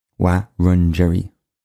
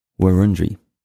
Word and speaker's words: Wurundjeri: One awesome listener recently pointed out I was slightly mispronouncing Wurundjeri, so I've just updated the end section. But I'm probably still making hames of it. It's a very cool but tricky language. Wurundjeri. Wurundjeri. I think that's right.